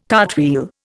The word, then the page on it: cartwheel: Indian